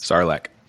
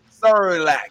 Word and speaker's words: Sarlacc: Sarlacc. – Sarlacc.